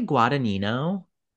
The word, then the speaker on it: Guadagnino: I say Guadagnino. – Guadagnino.